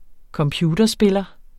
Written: computerspiller

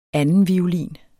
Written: andenviolin

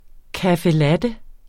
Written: latte

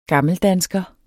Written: gammeldansker

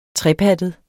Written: trepattet

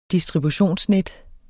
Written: distributionsnet

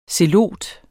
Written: zelot

